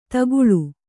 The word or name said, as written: ತಗ್ಗಾಳು